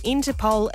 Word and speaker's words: IN2POL: His name is Interpol, and that is spelled I-N-2-P-O-L, formerly Interpol the word, but I guess you can probably tell why he had to change that one.